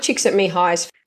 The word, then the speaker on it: Csikszentmihalyi: Mike Csikszentmihalyi, you pronounced his name so well, Mihai Csikszentmihalyi, is the founder of Flow.